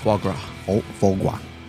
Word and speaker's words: foie gras: Foie gras.